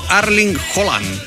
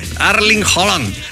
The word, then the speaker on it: Erling Haaland: La pronunciación correcta de el golpeador noruego debe sonar como Erling Haaland. Ah, del goleador, no el golpeador. Esos son otros. – Cuidado. – Erling Haaland.